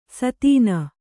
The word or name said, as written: ಸಾದಣ